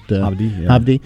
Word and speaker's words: Hovde: So, I saw that a contender for Tammy Baldwin's seat announced this week, Eric Hovde. I'm not sure how to say it, but Hovde. Yeah.